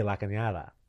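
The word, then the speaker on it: La Cañada: It's supposed to be La Canada. La Canada.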